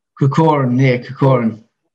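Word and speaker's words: Corcoran: Let's talk about you winning that British welterweight title against Gary Corrigan. Have I said that right? – Kokoran.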